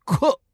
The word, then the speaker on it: Koch: It's spelled K-O-C-H, but it is pronounced Cook Trucking.